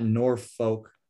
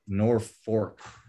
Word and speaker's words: Norfolk: It's not Norfolk, Nebraska, although it's spelled F-O-L-K, but it's Norfolk.